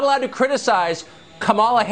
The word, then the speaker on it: Kamala: Her name is pronounced comma, like the punctuation mark, la, Kamala.